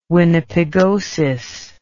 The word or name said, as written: winnipegosis